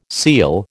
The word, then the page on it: seel: American English